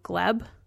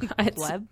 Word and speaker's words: Glebe: He went missing from his home on Alexandra Lane in Gleb. Probably pronouncing that wrong. – Gleb? – It's G-L-E-B-E. Gleb. – Glebe? – Glebe?